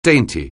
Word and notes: dainty: anglais

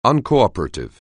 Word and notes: uncooperative: anglais

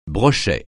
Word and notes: brochet: francais

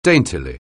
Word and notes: daintily: anglais

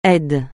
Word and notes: aide: français

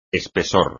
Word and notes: espesor: espagnol

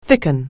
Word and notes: thicken: anglais

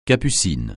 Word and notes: capucine: francais

